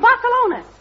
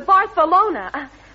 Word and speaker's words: Barcelona: I mean to Barcelona. That's where he wants to go. – Where did you get that Barcelona stuff? – Because in Spanish, the C-E is always pronounced as th.